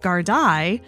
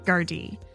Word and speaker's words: Gardaí: I went through the entire episode saying Gardaí, when it should be, at least according to the internet, Gardaí.